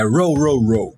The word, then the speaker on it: Row, Row, Row: Como é que pronuncia isso aí? Eu não sei. – É Row, Row, Row.